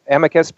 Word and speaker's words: amicus: The amicus briefs, or amicus briefs, as some people call them, and there's no right or wrong way to pronounce that word, by the way. That is clear from Webster's. I've looked You're giving me a pass? – No, yeah, I've looked it up myself and several times, and you can pronounce it both ways.